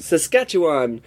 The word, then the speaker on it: Saskatchewan: Okay, now I looked up how to pronounce this yesterday. – Mountain. – Saskatchewan. – Saskatchewan. – Saskatchewan. I'm in Saskatchewan, yeah.